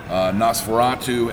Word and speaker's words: NOS4A2: He did Lock and Key, Heart-Shaped Box, Nosferatu, NOS4A2. I had to think about it because it's spelled like a license plate, which is a show on AMC.